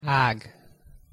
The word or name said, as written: ag